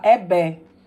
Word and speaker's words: Egbe: E aí se escreve EGBE, mas em Yorubá eles falam EBE. Então a gente fala EBE, Mostra de Cinema Negro.